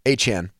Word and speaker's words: Achane: What's Miami's running back's pronunciation again? Achane? – Devin. I thought it was Achane. – Achane. – I think it is Achane. – Achane. – Achane.